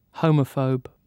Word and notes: homophobe: British English

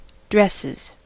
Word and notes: dresses: American English

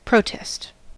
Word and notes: protest: American English

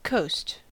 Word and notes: coast: American English

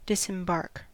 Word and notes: disembark: American English

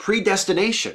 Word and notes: predestination: American English

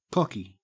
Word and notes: cocky: Australian English

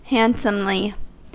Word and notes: handsomely: American English

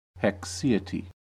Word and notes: haecceity: American English

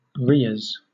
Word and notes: rheas: British English

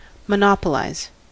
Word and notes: monopolize: American English

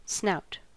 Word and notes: snout: American English

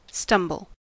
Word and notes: stumble: American English

